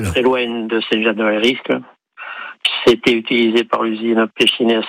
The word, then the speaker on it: Saint-Jean de Valériscle: Jean de Saint-Jean de Valériscle. C'est ça? Je me trompe pas dans la prononciation, oui. – Jean? – Oui, oui, c'est très bien, c'est ça.